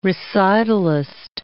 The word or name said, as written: recitalist